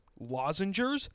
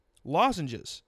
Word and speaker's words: lozenges: Lozengers? That's not a word. You're absolutely correct. Lozenges. To take a lozenge. Plural? To take multiple lozenges. That's the correct pronunciation.